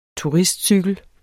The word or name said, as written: turistcykel